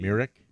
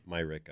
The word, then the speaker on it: Mirek: How do you spell it? – M-I-R-E-K. – That would be Myrick. – Myrick, I believe.